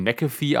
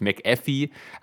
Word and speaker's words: McAfee: Soweit ich weiß, spricht man ihn McAfee aus und nicht McAfee, aber viele kennen ihn halt von seinem so, ja.